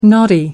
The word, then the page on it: knotty: American English